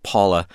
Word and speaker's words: Pavvla: It's spelled P-A-V-V-L-A, pronounced Paula.